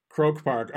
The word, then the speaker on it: Croke Park: Croke Park.